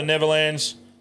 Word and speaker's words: Netherlands: It's spelt Nether. Lands. But I prefer Nether. – Yeah, mate, you go for it. – Well, how would you say it? – I say Netherlands. Yeah. – Nether? – Yeah. – Yeah, but why is it spelled with a D? – No, I think that's... – It's silent. – It's silent. Now, Keith, it's silent.